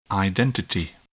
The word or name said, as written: identity